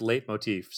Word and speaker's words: Leitmotif: And yes, it is pronounced Leitmotif, so you nailed it.